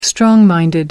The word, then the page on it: strong-minded: American English